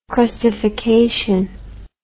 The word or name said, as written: crustification